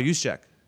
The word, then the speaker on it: Juszczyk: J-U-S. – Oh, Kyle Juszczyk.